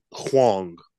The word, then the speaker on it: Hwang: I'm going to butcher his name, Hwang. I feel like I'm saying it wrong. – No, you said it right.